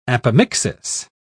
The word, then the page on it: apomixis: British English